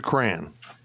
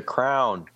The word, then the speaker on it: crayon: How do you pronounce that waxy stick that you write with as a child? It's a crayon. – It's a crayon. – Crayon. – Huh. No, it's a crown.